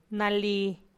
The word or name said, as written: rugwa low